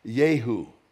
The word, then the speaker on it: Jehu: Now, in the Hebrew, it's pronounced Yehu.